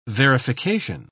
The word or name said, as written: verification